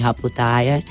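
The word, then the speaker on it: Haputale: Je to vlastně nad městem Haputále, nebo jak oni vyslovují Haputáje.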